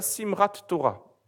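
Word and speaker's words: Simhat Torah: On appelle ça Simrat Torah.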